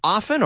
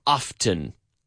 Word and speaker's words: often: Se puede decir often or often? Often? Often. Either one.